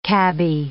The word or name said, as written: cabbie